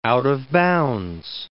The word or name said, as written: out-of-bounds